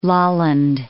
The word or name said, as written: Lolland